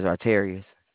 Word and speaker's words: Arterious: My name is Artarius. – Can you spell that, please? – Yes, sir. A-R-T-E-R. I-O-U-S.